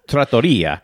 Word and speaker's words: trattoria: And then you pop in the oven for like an hour and a half and your whole house smells like a trattoria. Did I say that right? – It sounded like you were saying treachery, which is kind of scary, but trattoria.